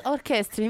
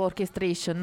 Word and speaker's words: Orchestrion: Infatti stavo un attimo guardando la pronuncia, perché mi viene da dire tipo Orchestration, non so perché. No, infatti ogni volta me lo so tipo ripetuto a mente, che era Orchestrion.